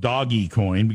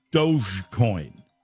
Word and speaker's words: Dogecoin: One of the cryptocurrencies is something, I want to call it doggy coin because that's the way it's spelled, but it's pronounced Dogecoin.